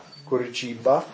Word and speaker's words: Curitiba: Curitiba.